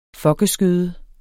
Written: fokkeskøde

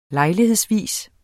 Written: lejlighedsvis

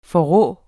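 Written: FORRÅ

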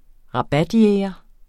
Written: rabatjæger

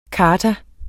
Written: charta

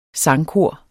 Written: sangkor

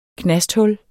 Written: knasthul

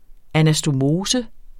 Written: anastomose

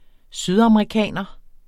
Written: sydamerikaner

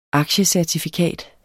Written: aktiecertifikat

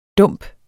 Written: dump

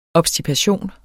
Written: obstipation

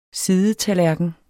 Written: sidetallerken

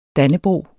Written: dannebrog

